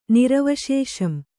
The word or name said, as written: ನಿರವಶೇಷಂ